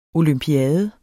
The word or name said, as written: olympiade